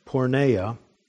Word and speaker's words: porneia: The words sexual immorality in my text here are the translation of one Greek word, which is pronounced porneia. We get the word pornography from that. It isn't the more common word for adultery. Sexual immorality or porneia, this word is a broader term than that.